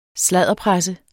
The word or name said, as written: sladderpresse